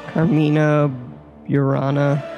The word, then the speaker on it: Carmina Burana: It's Carmina Burana.